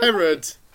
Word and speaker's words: Herod: Herod.